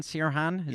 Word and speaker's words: Sirhan: Sirhan, is yes, that how you pronounce Sirhan.